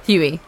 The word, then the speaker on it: Hughley: How do you pronounce Huey. – That? Huey. So the G is silent. – Yeah. So it's spelled like hug, hey.